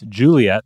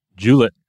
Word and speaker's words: Juliet: This is Juliet, or Juliet, right? If you're depending on how you're, what line from the play you're using, sometimes you got to hit two syllables instead of three on that.